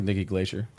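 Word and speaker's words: Nikki Glaser: Because even like Nikki Glacier, I think I'm mispronouncing her name. – Yeah, Nikki Glacier. – OK, Nikki Glacier.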